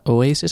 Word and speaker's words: Oasis: We hebben het natuurlijk aan het begin over Oasis gehad. Zeg ik dat goed? Oasis? – Zeker.